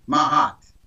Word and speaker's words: Maat: The African concept of spirituality is the concept of Mahat. Mm-hmm. It's spelled M-A-A-T, Mahat.